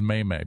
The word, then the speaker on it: meme: That's a May-May. – The correct pronunciation is May-May, please.